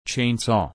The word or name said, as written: chainsaw